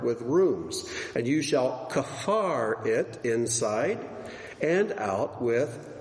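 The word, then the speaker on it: kafar (כפר): The third word is kafar. Kafar. Say kafar. Okay, good children. All right. This is also a verb, but you could pronounce it kippur, and it becomes a noun, like in Yom Kippur.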